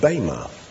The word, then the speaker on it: bema: It's called, the word is pronounced bema.